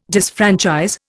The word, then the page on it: disfranchise: Indian